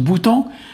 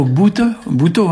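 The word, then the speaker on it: bountañ: Alors aujourd'hui, on va parler d'un verbe qui s'écrit boutant, il se prononce boutant, ou bountan, ou bouto, hein. Ici on dit plutôt bouto, hein.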